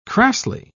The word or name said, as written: crassly